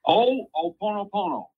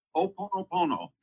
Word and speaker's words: Ho'oponopono: Is it the concept, the Hawaiian concept of, I think, Ho'oponopono? I can't pronounce that well. – Ho'oponopono. – Yes. – Ho'oponopono.